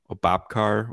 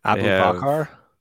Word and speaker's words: Aboubakar: They have Obapkar, whatever his name is, Vincent Obapkar, however you pronounce it. – Aboubakar.